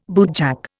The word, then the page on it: bootjack: Indian